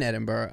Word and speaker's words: Edinburgh: Edinburgh. You're in Edinburgh, I think, right now.